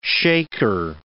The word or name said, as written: shaker